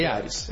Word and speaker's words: Eares: Isso, Eares. Eares. É, se fosse puxar para o inglês, a gente mudaria a pronúncia, mas em português, como nós somos brasileiros, pronto. – Eares, como está escrito.